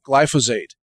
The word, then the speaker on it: glyphosate: And in case you don't know how to spell that, it's glyphosate, glyphosate. It's similar to phosphate because that's actually, believe it or not, that's part of the molecule, but it's not the same as phosphate. It's glyphosate.